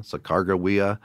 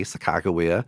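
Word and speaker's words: Sacagawea: At one point, they did call a river Bird Woman's River, which in the Hidatsa language, that would be Sakagawea. And that's the only time in the journals that I know of where they actually tried to spell it phonetically with a hard G sound, Sakagawea.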